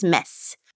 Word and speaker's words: Metz: So I think it's Metz.